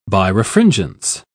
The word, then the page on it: birefringence: British English